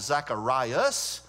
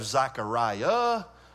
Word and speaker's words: Zechariah: By the way, either pronunciation is correct. You may have a Bible that calls it Zacharias, or you may have Zechariah. Either one is correct.